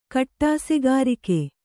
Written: ಕಟ್ಟಾಸೆಗಾರಿಕೆ

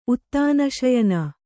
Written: ಉತ್ತಾನಶಯನ

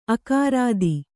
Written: ಆಕೃತಿ

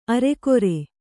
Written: ಅರಗುರಿ

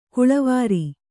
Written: ಕೊಳವೇರು

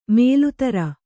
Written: ಮೊಲೆತೊರೆ

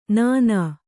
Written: ನಾನಾ

